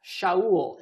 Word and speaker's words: Shaul: Shaul in Hebrew sounds like the word Sha'al. You see, the people of Israel asked for a king. They Sha'al asked for a king, and they got Shaul.